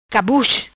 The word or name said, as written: Cabuz